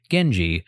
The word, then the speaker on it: Genji: While the character for Taira can also be pronounced Heikei, and the character for Minamoto can also be pronounced Genji,